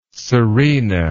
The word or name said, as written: serena